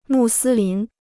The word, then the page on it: 穆斯林: mù sī lín